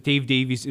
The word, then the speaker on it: Dave Davies: I want to remind folks that Dave Davies is our guest.